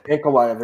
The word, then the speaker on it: Ankalaev: Ankalaev, there you go.